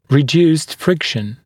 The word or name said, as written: reduced friction